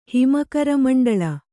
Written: ಹಿಮಕರಮಂಡಳ

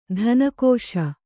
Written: ಧನಕೋಶ

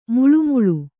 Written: ಮಳಮಳ

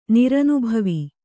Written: ನಿರನುಭವಿ

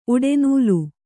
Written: ಉಡೆನೂಲು

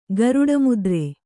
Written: ಗರುಡಮುದ್ರೆ

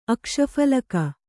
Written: ಅಕ್ಷಫಲಕ

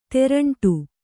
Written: ತೇರಂಟಿ

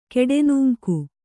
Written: ಕೆಡೆನೂಂಕು

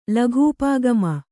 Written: ಲಘೂಪಾಗಮ

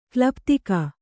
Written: ಕೊಲೆಪಾತಕಿ

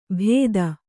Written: ಭೇದ

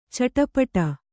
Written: ಚಡಪಡ

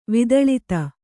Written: ವಿದಳಿತ

